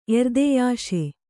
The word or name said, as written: ಎರ್ದೆಯಾಶೆ